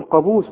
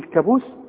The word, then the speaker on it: قابوس: وعندك بقى في الكلمات البلد بتاعتنا بقى المتداولة، عمرك ما تقول على القانون كانون، تقول كانون، قانون، وتقول قابوس، ما تقولش ايه، هتقول كابوس، قابوس، قرار، ها؟